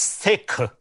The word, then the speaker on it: Sikh: But correct pronunciation is Sikh. Like, it's like a similar way you will say Tim, right? Like T-I-M. Mm. So that way, like Sikh, like Sikh, instead of Sikh.